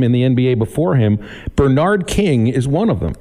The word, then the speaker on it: Bernard: They always put the emphasis on that first syllable. They call him Bernard.